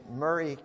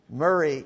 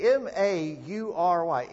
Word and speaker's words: Maury: Now I'm telling you, Murray County, you better say that right or we're going to know that you ain't from around here. Murray County, Tennessee. M A U R Y. M A U R Y. And for all the world, that looks like that ought to be Maury, maybe.